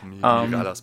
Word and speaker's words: Mirallas: Und zwar heißt es Kevin Antonio Miralas, schreibt man, es wird wahrscheinlich Miraya oder sowas aussprechen. Ach, Mirallas ähm, passt schon.